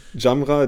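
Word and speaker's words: Gyamerah: Das war mit Brown und äh, Giamera, wie wird er ausgesprochen? – Jamra. Giamera. – okay. Ähm, ja, wirklich der Jamra. – Jamra, der punktet äh, extremst gut.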